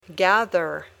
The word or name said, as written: gather